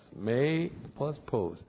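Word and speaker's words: May plus pos (μήπως): So here's M, long E, P, long O, S. May plus pos.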